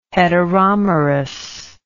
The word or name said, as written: heteromerous